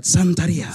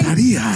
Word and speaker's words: Santeria: So can you tell me what it is about Santeria by Sublime that you love? – And you have to say it like that, Santeria. – Sorry. – Santeria.